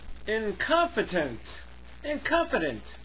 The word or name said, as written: incompetent